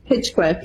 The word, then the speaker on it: Hinchcliffe: Is it Hinchcliffe? Is that how he pronounces it? – Hitchcliffe, yeah.